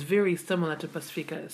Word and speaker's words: Pacifica: So the P in Ngangana Samoa is almost pronounced like a B in English. – Mm-hmm. – So repeat after me. Pasi. – Pasi. – Fica. – Fica. – Pacifica. – Pacifica. – Pacifica. – Pacifica. – So we really emphasize the F-I in Pacifica. So that third syllable's really important to pronounce. So once again, Pasi.